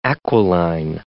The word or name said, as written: aquiline